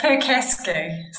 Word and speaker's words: Percasky: It's Percasky.